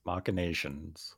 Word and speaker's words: machinations: M-A-C-H-I-N-A-T-I-N-O-S. I've never heard of this word. – M-A-C-H-I-N-A-T-O-S? – No. M-A-C-H-I-N-A-T-I-N-O-S. – Machinatons? I don't know. – Machination. – Machinations? – Machinations.